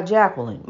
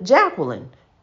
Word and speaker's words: Jacqueline: So see, you know, I go by Jacqueline, my name. Jacqueline is my name. You have a hard time pronouncing. I'm sorry. You know, just go do the best you can. I understand. You know, it's difficult. You know, but Jacqueline, you know, don't say Jackie, because then you twist me.